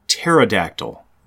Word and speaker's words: pterodactyl: It is pterodactyl. The P is silent.